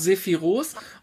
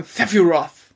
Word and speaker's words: Sephiroth: Also, frü also früher hat man gesagt Sephiroth und jetzt sagt man Sephiroth.